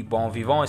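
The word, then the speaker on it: bon vivant: बॉम विवों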